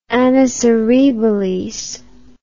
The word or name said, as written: annus horribilis